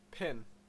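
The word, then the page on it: pin: Canadian English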